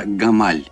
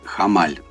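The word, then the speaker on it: Hamal: Альфа или ярчайшая звезда – созвездии Овна – это звезда Гамаль или Хамаль. Ну, по-разному ее произносят, хотя написание одно и то же.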